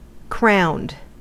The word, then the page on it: crowned: American English